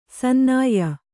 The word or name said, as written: ಸನ್ನಾಯ್ಯ